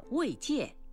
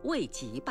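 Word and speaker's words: 慰藉: 草字头籍是多音字，一读籍，践踏、侮辱的意思；另一种读音戒，安慰、抚慰叫慰戒。您没有把它读成慰籍吧？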